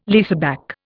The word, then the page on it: leaseback: Indian